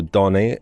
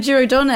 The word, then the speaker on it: Giro Donne: We went from there to the Giro Donne. Is that how you're pronouncing it? – Yes. Donne. How else would you go for it? – I don't know.